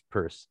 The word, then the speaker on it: Peirce: And the two major theorists, of course, Saussure and Peirce, it's spelled Pierce, but it's pronounced Peirce, the American philosopher.